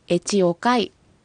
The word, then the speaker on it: エチオカイ: はい、このあなたたちを示す認証代名詞はエチオカイと発音します。